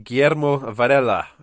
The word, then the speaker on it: Guillermo Varela: Rich, Guillermo Varela, who is, as you rightly pointed out...